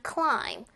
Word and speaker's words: climb: 자, 이 친구 climb 같은 경우 발음할 때요. climb, b 하고 B 사운드가 들리면은 에러입니다. 저 B 사운드를 의식할 필요가 없어요. 원어민들도 저거 묵음 처리하기 때문에 그냥 climb, climb, climb 이렇게 편하게 발음하시면 됩니다.